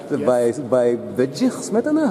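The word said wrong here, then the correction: Bedřich: How do you pronounce his name? – Bedjich. Bedjich. Bedjich Smetana. It's a little bit difficult. R. – Yeah. – Small accent. – You yeah. put the tongue behind the teeth.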